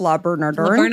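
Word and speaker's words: Le Bernardin: La Bernardin.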